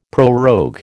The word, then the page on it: prorogue: American English